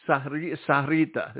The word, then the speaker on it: Sahuarita: The Spanish version of the enunciation would be Sahuarita. But it also goes by the Sahuarita as well. But Sahuarita is the formal term.